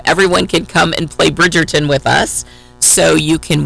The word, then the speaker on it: Bridgerton: I mean, I tell you, there's no denying that Bridgerton, right, am I saying it correctly, is one of the most watched series on Netflix.